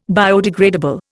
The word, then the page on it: biodegradable: Indian